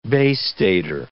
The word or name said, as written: Bay Stater